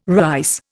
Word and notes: rice: Indian

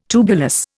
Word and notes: tubulous: Indian

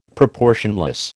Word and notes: proportionless: American English

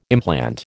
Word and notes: implant: American English

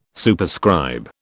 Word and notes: Superscribe: British English

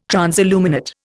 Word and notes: transilluminate: Indian

